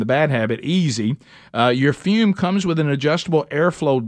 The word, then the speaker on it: Fum: Go to TryFume, that's T-R-Y, then F-U-M. All right, even though it's pronounced Fume, it's spelled F-U-M.